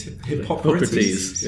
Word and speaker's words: Hippocrates: Not necessarily they knew that, but I always get this name wrong. Hippocrates? – Hippocrates, yeah.